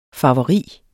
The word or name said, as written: farveri